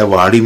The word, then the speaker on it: وہاڑی: وہاڑی پنجاب پاکستان سے یہ آپ نے پیارا سا خط ہمارے لیے ارسال کیا ہے۔ وہاڑی میں نے صحیح تلفظ کیا؟